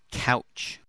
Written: couch

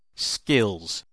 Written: skills

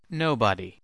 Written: nobody

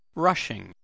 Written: rushing

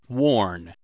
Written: warn

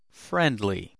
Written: friendly

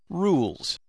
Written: rules

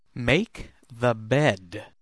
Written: make the bed

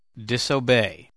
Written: disobey

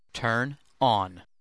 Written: turn on

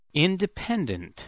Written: independent

